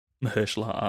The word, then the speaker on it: Mahershala: Michelle Ali. Mahershala Ali. – Yeah. Mahershala. Um, yeah.